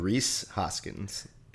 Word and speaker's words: Rhys Hoskins: Rhys Hoskins.